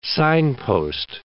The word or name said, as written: signpost